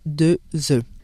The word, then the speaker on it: œufs: Les lettres O et E soudées sont un digramme et se prononcent E. Notez bien, au singulier, on dit œuf, mais au pluriel, on dit deux œufs.